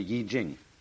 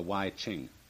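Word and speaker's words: Yi Jing (易經): called the Yi Jing, not the Wai Ching.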